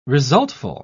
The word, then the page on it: resultful: British English